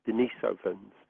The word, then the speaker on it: Denisovans: And the correct pronunciation, and obviously there are two ways of pronouncing it that certainly have come into popular tradition, but the original and the correct way of pronouncing Denisovans is, as I just said, Denisovans.